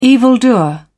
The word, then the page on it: evildoer: British English